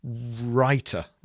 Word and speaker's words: Rytr: So it's a copywriting app, R-Y-T-R, writer. That's exactly how it's pronounced, R-Y-T-R, and it's R-Y-T-R.me.